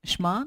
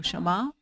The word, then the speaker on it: Shema: V písmu v původně hebrejským a v hebrejštině je slovo, který se vyslovuje šma nebo šama.